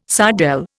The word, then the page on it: sardelle: Indian